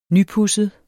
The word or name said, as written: nypudset